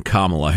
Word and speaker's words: Kamala: So I was going to say something uncharitable about the Democratic candidate for the vice presidency, one Kamala Harris. We got an email from a guy. Who was it? Bob. Said, here's how you remember how to pronounce Kamala. It starts like comrade.